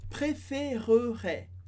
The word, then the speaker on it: préférerais: Se a gente fala bem devagar, a gente pronuncia esse E no meio, sem acento, e ficaria préférerais.